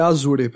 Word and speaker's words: Azure: Com eu nunca sei pronunciar essa palavra, é Azure? Azure? – É Azure, pelo que eu sei.